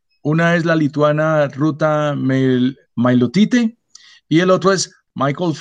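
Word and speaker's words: Meilutytė: De Ruta, sí, se pronuncia así, Mailutite.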